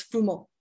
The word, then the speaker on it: Fumo: Most English people pronounce it Fumo, but in Italian, it's Fumo.